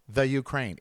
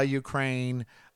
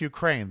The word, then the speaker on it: Ukraine: The Ukraine. It's a topic for me. The Ukraine, the entertainment news, the whatever. So it's a topic, but it's pronounced Ukraine. There's no the. It's Ukraine. And